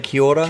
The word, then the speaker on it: kia ora: Kia ora. Do I say kia ora? – Kia ora, yes. Thank you. – Kia ora, good pronunciation.